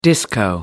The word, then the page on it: disco: American English